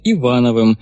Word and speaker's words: Ивановым: Но сейчас меня чаще называют Ивановым, ударение на первом слоге по-офицерски. Есть Агосфер, нет его, но далекая планета, вот она, протяни руку.